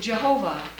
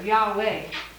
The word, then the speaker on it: Yahweh: So in the King James time, they would put in the vowels E, O, A, and pronounce it Jehovah. That's why we either call it Jehovah or now we... And now, more scholars just put an A here, Yah, and an E here, Yahweh.